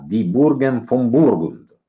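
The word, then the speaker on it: Die Burgen von Burgund: Quindi Grob ci presenta Di Burgen von Burgund. L'ho pronunciato bene? – Benissimo uno spettacolo. Senza ironia, assolutamente, proprio come si scrive e si legge.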